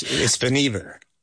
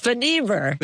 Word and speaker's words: Vannevar: You can tell us how to pronounce it here. – It's Vannevar. – It's Vannevar. – But everybody called him Van. I guess we everybody that made it a lot easier. I was putting the accent on the wrong syllable there.